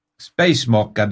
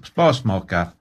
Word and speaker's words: Spasmaker: Nej, S-P-A-S. – Ja. – M-A-K-E-R. Spacemaker, nej det ska uttalas på norsk, spasmaker.